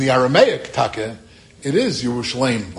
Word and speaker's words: Yerushalayim: It's spelled in the Aramaic without the Yud. But in the Hebrew, we pronounce it Yerushalayim.